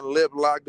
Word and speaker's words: Liplock: Comedian Liplock. This is on IG. Comedian Liplock 547 is spelled the way it sounds. You know what I'm saying? No gangbanging involved. It's just strictly how you spell it.